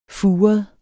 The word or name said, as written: furet